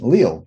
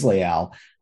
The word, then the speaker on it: Leal: And, you know, some people pronounce his name Leal, but it is Leal.